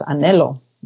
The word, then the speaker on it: anhelo: So one of the word that my father would use a lot is anhelo, which is it's spelled A-N-H-E-L-O. And at first I was like, how do you say that? Anhelo, anhelo.